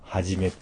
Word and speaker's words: 春: ビルにあるロボットレストラン、はじめっていう。あの、漢字で春って書いて、はじめって読むんですけど、の武者ロボットが踊ってましたよ。